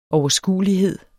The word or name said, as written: overskuelighed